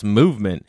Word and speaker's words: MVMT: That's MVMT Watches, pronounced Movement.